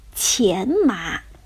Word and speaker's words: 荨麻: 上面一个草字头，下面是一个寻找的“ 寻”，这个字呢是多音字，既可以读作“ 钱”，也可以读作“ 寻”，但是在这里呀，它正确的读音应该是“ 钱”，钱麻。